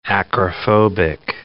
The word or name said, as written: acrophobic